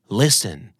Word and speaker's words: listen: listen ตัวทีไม่ออกเสียงเลยนะครับ